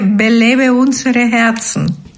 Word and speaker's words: Belebe unsere Herzen: Y diga, así, enséñanos cómo se dice Aviva Nuestros Corazones en alemán. – Se dice Belebe unsere Herzen.